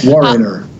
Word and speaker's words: Wariner: Now, your latest single, Stomp a Little Harder, features country legend and co-writer Steve Wariner. I hope I'm pronouncing his name right. – It's Wariner.